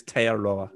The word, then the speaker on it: Aotearoa: The tongue wrong. Aotearoa. It's Aotearoa.